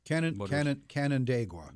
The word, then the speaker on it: Canandaigua: the Canandaigua, New York. – Canandaigua. – That's it.